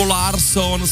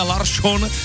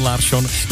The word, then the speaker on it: Larsson: Místo Persson nebo Larsson jsme slyšeli Persson a Larsson a fanoušci se ptají, co to znamená. To je nějaká změna? Proč se to děje? My to víme, protože právě takhle se to ve švédštině opravdu vyslovuje. Respektive vyšlovuje. Takže my jsme Persson a Larsson celé ty roky říkali špatně. Správně je to Persson, Larsson.